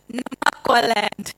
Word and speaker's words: Namaqualand: So in that, I believe that really, honestly, I believe the church in America right now is kind of like Namaqualand. Is that how you say it? Namaqualand? Say it again. Say it again. – Namaqualand. – Oh, I like that.